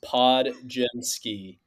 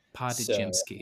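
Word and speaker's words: Podziemski: the pronunciation that I wrote down because I knew I would run into this is Podjemski. Podjemski.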